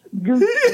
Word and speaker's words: Gooch: Gouch, Gooch. Just remember, like Gucci, you know.